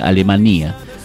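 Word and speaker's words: Alemanía: Cuando hablaron de Londres, me acordé de Alemania, con acento en la I. Ah, sería Alemania.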